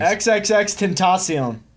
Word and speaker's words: XXXTentacion: Yeah, I don't know him. X Tenacious. I know him from TMZ, his face. Tentacion? Wait, I mean, I'm being told two different things. – XXXTentacion.